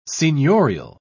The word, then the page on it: seigniorial: British English